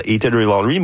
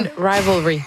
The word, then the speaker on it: Heated Rivalry: Et évidemment, on a beaucoup parlé dans la dernière année grâce à la trame sonore de Heated Rivalry, mais... Je l'ai bien prononcé, je pense, c'est la première fois. – Non, Heated Rivalry.